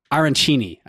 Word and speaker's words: arancini: the arancini, I believe, John, did I say that right? – Yeah.